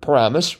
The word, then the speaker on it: Paramus: This is the pronunciation of Paramus, which you just heard, which is indeed a well-known center for mall and big box retail culture.